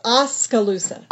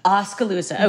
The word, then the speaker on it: Oskaloosa: It is Oskaloosa. – Oskaloosa.